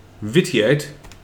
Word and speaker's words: vitiate: We need the real pronunciation here. What is it? – Real pronunciation would be vitiate. – Vitiate? All right. – As okay. – opposed to vitinine.